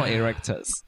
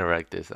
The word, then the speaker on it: erectus: You know, like Homo erectus. It's different from that. – You like saying Homo and erectus.